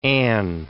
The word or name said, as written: Anne